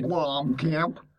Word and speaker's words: Blomkamp: Did I say his name right? – Blomkamp.